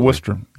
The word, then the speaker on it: Worcester: How do you say Worcester? Do I say it right? You just kind of plow through, right? – Absolutely. – Worcester, W-O-O-S-T.